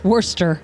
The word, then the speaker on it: Worcester: And Jeff says, except in Massachusetts where it is pronounced Worcester. – Worcester. Yeah, that's right.